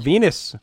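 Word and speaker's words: Venis: Mr. Val Venus, rhymes with penis, targeted Runnels and his preaching and decided to make his life a living hell.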